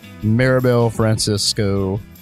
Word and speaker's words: Maribel Francisco: But I do have instead Maribel Francisco.